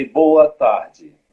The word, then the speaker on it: boa tarde: And if, if you want to say uh, good afternoon, you, you can say boa tarde. Okay. Boa, boa. Boa. Tarde. Tarde. Ah. Perfect. Okay. Perfect pronunciation.